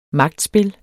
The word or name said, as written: magtspil